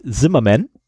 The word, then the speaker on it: Zimmerman: Ich gehe davon aus, der Typ ist Amerikaner, deswegen versuche ich das mal so auszusprechen, wie die das aussprechen würden. Phil Zimmerman, obwohl da Zimmermann steht.